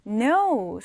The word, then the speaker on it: know: 자, 그러면 우리 다음 단어 No 살펴볼게요. No, 알다 라는 표현인데요. 여기에서 K는 발음하지 않는, 소리 나지 않는 무음입니다. No, 큰 O가 아니고요. No, K가 없는 것처럼 발음을 해주시면 돼요.